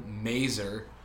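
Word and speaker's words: Mazur: Yeah, thanks to the biggest overreaction of the century from terrible principal Mazer. – Yes. – Rhymes with laser.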